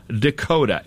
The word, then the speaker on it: Dekoda: And it is called the Dekoda, and it's spelled D-E-K-O-D-A. Write that down for your Christmas shopping list, okay? – D-E-K-O-T-A, Dekoda.